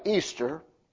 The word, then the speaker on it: Eastre: but the word Easter is believed to come from the word E-A-S-T-R-E, pronounced still the same way, Easter, which is an Anglo-Saxon name for a pre-Germanic goddess of spring and fertility.